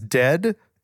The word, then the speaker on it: Dehd: The band's name is Dehd. It's spelled D-E-H-D, in case you want to Google it.